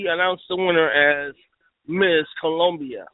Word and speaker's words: Colombia: We have, on one end of the spectrum, Ms. Colombia, or Colombia, as you would pronounce it, Colombia.